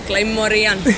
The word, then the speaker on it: Claymorian: Dakle, Underground Radio, Domus emisija, ovde smo iz benda Clay Morina. – Claymorian. – Ipak nisam uspela da izgovorim, hvala.